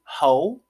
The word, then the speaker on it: Heaux: Heaux Tales, like Heaux Tales. It's spelled H-E-A-U-X, you know, because we classy out here, Tales, you know.